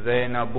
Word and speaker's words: زینب: یہ غیر منصرف اس لیے تنوین زینب نہیں پڑھنا بھائی.